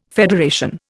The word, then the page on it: federation: Indian